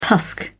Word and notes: tusk: British English

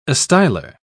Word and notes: astylar: British English

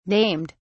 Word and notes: named: British English

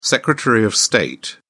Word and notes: secretary of state: British English

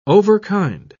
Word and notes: overkind: British English

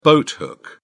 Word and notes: boathook: British English

